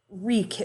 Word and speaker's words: Reek: Reek. It rhymes with leak.